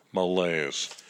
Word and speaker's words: malaise: There's a big word, malaise. Rhymes with mayonnaise.